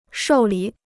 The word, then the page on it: 受理: shòu lǐ